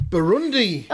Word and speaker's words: Burundi: I'm going to go for Tunisia versus Bernardi. – I'm going to try and guess what these are from how you say it. Say it again. Bernardi. – Yeah. – Benidorm? – Bernardi. – Give me a look. Tunisia versus Burundi. – Oh, Burundi.